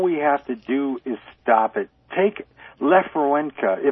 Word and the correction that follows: Lech Wałęsa: Am I saying that right? Lefrawenka? – Lech Wałęsa? – Yes.